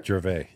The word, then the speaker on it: Gervais: Did I pronounce it pretty good? – Yeah. – Yeah? – You're good. – Yeah? – Yeah. – For sure? – Gervais. Yeah.